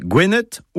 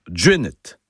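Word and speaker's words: Gwened: Alors son nom breton, c'est Gwenet ou Djuenet, en prononciation du breton du Morbihan.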